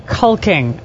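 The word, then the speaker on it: caulking: Doing some cocking. – It's actually pronounced culking, okay? – Culking.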